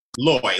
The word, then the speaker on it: Lois: It looks like Lois, L-O-I-S, but it's pronounced Loy, like Joy.